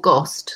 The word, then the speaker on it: GOST: The publisher, I heard you say, I don't know how you pronounce it, but let's spell it G-O-S-T. – It's called Ghost, so Ghost without the H.